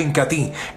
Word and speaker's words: Incatea: Se escribe Incatea, pero se pronuncia Incatí,